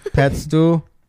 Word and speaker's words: Louis Pasteur: Pretty sure he's not saying that name right. – Louis Pasteur. – Louis Pasteur. I'd like to see it. – It's fine. I know he's saying it wrong, though. – L-O-U-I-S-P-A-S-T-E-U-R.